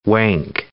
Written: wank

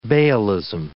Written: baalism

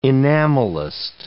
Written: enamelist

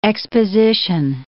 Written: exposition

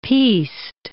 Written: piste

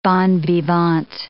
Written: bon vivant

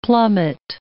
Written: plummet

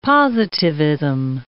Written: positivism

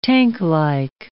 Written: tanklike

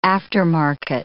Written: aftermarket

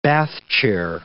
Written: bath chair

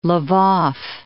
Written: L'vov

